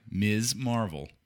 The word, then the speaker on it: Ms. Marvel: But anyway, we are here to talk about the latest in the Marvel Cinematic Universe, their latest Disney Plus series, Miss Marvel. Miss Marvel. – No, it's Ms. M-S period. Ms. Marvel.